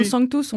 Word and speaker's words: Sanctus: Vous faites la prononciation à l'allemande, tout d'un coup, je me demande. – Oui, mais l'homme Sanctus, on peut faire un S assez fort. Parce que en allemand, cet S-là n'est pas Z.